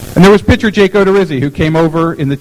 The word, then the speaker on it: Odorizzi: The Tampa Bay Baseball Writers Association Outstanding Rookie for 2014 is Jake Odorizzi.